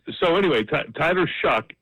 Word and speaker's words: Shough: They have a quarterback, Tyler Shuck. It's spelled S-H-O-U-G-H. Okay, I know that doesn't make sense, but it's Scottish Gaelic is what it is.